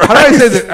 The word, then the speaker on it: cauterized: Quarterize is when you heat up a quarter, and then you cauterize the wound. – Is that really? – No. – Cauterized. I'm saying it wrong then.